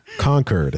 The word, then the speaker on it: Concord: Is it Concord? Concord? – You caught me. You caught me. That's right. You know, old habits die hard. Concord. That's right.